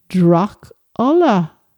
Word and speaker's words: Drachfhola: Or maybe she told young Bram of the far on Drach Folla, D-R-A-C-H-F-H-O-L-A, meaning man of the bad blood. Folla is blood. Interesting that the last two words, Drach Folla, are pronounced Drach Ulla, very close to Dracula.